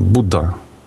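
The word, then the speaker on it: Будда (बुध): И имя Меркурия на санскрите – это Будда или Будха. Слово «хэ» в слове, буква «хэ» в слове не читается так, оно мягкое в санскрите, поэтому там, «Будда» вот так проговаривается.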